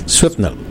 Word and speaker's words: Swapnil: So the first name is? – Swapnil. – Swapnil. – Swapnil, Swapnil. – Yeah. – Now I can pronounce.